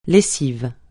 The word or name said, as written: lessive